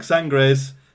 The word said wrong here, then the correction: Sagres: Sangres Preta. Sangres Bo Bahima. Sagres Preta. Sagres Bohema.